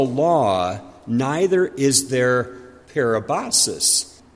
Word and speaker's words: parabasis: The first word we come to today is pronounced parabasis, parabasis. And the typical translations here would either be transgression or violation.